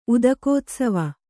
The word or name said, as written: ಉದಕೋತ್ಸವ